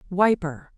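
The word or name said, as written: wiper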